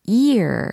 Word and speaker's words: ear: 어, 년도를 이야기할 때는 year 이렇게 발음하고 귀를 이야기할 땐 ear 이렇게 발음한다라는 것도 점검하시면 좋을 것 같아요.